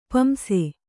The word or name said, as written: ಪಾಂಸು